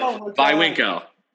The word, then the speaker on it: Viwinco: I was like, Viwinco, Viwinco, yeah.